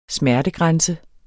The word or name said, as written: smertegrænse